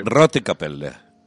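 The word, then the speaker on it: Rote Kapelle: También se la, Rote Capella.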